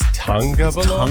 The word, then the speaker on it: Tagovailoa: But Tagovailoa. See, I say Tagovailoa because it's spelled T-A-G. – And it's Tagovailoa? – Tagovailoa.